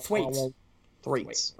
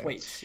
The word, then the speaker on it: Thwaites: Thwaites. – Okay. – Thwaites, yeah.